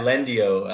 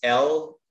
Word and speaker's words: Lendio: Yeah, Lendio. It's Lendio. – Okay. – It's almost like the Spanish pronunciation of the word.